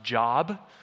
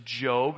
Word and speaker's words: Job: It looks like it's pronounced Job, okay? But it's Job, and he's a man, and we're going to talk about his life.